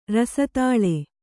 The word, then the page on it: ರಸದಾಲೆ: kannada